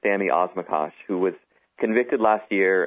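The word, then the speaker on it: Osmakac: It's the story of a guy named Sammy Osmakak. Is that right? – Osmakash is how it's pronounced.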